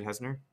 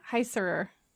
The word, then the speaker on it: Heisserer: And I really should read the short story to figure out how exactly he wrote it, because however, would you say his name is David Heisner? – Heisserer.